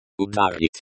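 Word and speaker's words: Udar (удар): The letter, u, in. Udar. Pronounced udar, is pronounced like the, u, in, moon. The letter, a, in. Udar. Pronounced udar, is pronounced like the, a, in, father.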